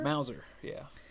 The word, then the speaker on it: Mauser: how do you say that kind of gun? Mauser? – Mauser, yeah.